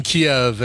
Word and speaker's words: Kyiv: It's always through chicken Kyiv and K-I-E-V and all that. It's really pronounced Kyiv, and that's the Ukrainian spelling and pronunciation and stuff.